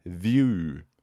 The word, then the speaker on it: Vue: View, som jeg ganske sikker på at det uttales View, altså Vue.